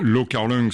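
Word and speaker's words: Lau Kar-leung: réalisé par Lo Carling. J'espère que je le prononce bien. – Lo Carling, c'est la prononciation cantonaise, et Liu Xiaoliang, c'est la prononciation mandarin.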